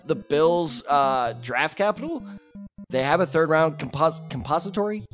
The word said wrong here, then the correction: compensatory: Compensatory. – I knew it. – Because it's compensation.